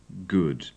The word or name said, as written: good